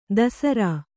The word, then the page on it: ದಸರಾ: dasarā